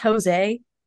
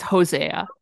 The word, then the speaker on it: Hosea: Is this pronounced Jose? It's Hosea. Jose? Oh, okay. It's like it's a Jose in the Bible.